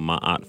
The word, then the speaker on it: Ma'at: There's a philosophy called Ma'at philosophy that stems from ancient Kemet, currently called Egypt. Ma'at is spelled M-A-A-T.